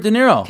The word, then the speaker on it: De Niro: De Niro.